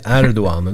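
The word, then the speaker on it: Erdoğan: Erdogan, det uttalas i Erdogan. Det har man lärt sig. Med det här special G-et är det kanske.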